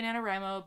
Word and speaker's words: NaNoWriMo: And to be fair, there used to be an FAQ on the website that said, how do you pronounce it? And they were like, we say NaNoWriMo, but you can say whatever you want. There's no official pronunciation.